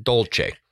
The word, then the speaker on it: dolce: I just don't want to get another message about butchering the language because I said dulce instead of dolce.